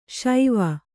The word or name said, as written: ಶೇವ